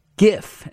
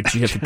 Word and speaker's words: GIF: So GIF is actually pronounced GIF, as they're saying. GIF, not GIF, like a GIF is a peanut butter, I suppose.